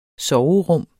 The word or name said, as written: SOVERUM